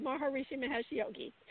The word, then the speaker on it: Maharishi Mahesh Yogi: Yeah, you said the name perfectly, Maharishi Mahesh Yogi.